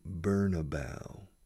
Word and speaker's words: Bernabéu: they say Bernabeu. Quema una proa, la proa de un barco se dice bow. Burn, como quemar, burn a bow, Bernabeu, como si fuera quema una proa.